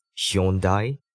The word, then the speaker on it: Hyundai: 那坐在 Hyundai 的后座，这个车子的牌子到底应该要怎么念呢？就是 Hyundai。